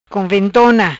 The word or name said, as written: Conventona